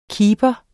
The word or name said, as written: kiper